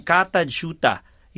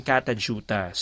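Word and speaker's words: Kata Tjuta: Katajuta yun pa na i-pronounce siya. Pero pag in-spell siya, K-A-T-A, isang word na yun, tapos another word, T-J-U-T-A.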